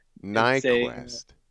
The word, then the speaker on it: Nyquist: It's spelled N-Y-Q-U-V-E-S-T. So we'll give you people a couple seconds to guess how is that pronounced. And the answer is Nyquist. – Nyquist. – Yeah, the sneaky silent V in there always throws people for a loop.